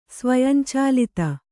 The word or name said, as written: ಸ್ವಯಂಚಾಲಿತ